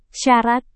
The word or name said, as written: syarat